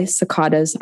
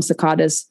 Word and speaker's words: cicadas: Here on the East Coast, we're about to be overrun by cicadas. I call them cicadas because I'm from California, but cicadas, I guess, is the proper pronunciation.